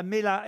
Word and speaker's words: Melha: Ah, Mela. – M-E-L-H-A. – C'est comme une pêche Melba sans le B, quoi.